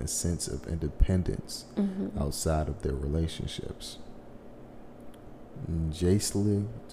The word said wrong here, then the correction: Jaleesa: how you say her name? – Jaleesa Tucker.